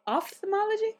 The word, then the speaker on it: ophthalmology: Ophthalmology? – Yeah, that PH sounds like an F. – I thought it was ophthalmology. – No, it's ophthalmology.